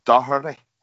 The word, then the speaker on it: Doherty: You know, that's how you pronounce it. Say that again. – Doherty.